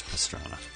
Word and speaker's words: Pastrana: It's Pastrana.